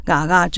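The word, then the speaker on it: GAGA: 在这部电影里面，你要观看什么是嘎嘎，因为嘎嘎也是我们的片的，我们没有取英文片名，我们是主语的片名，嗯啊，就 G A G A，可是我们用罗马拼音的发音叫嘎嘎，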